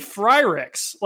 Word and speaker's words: Frerichs: Why you pronounce that name Courtney Freericks? Like it's Freericks, Courtney Freericks.